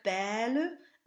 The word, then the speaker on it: belle: And when you see words like belle and tourterelle, be sure to linger on the open E vowel and not the double L that follows. These words will rhyme with the word for wing, elle, A-I-L-E, which is not to be confused with bataille and taille. The spelling I-L-L-E is a grouping that becomes the J glide, so there's no L sound in these words at all.